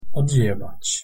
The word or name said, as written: odjebac